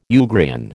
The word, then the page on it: Ugrian: American English